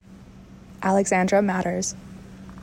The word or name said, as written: alexandra maters